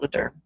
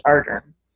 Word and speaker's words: Ardern: Lederna, I think. I can never say it. Did we say it correctly? Ardern. It's not Lederne. It's Ardern.